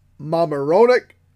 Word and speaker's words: Mamaroneck: From Decadent Ales in Mamaroneck, New York.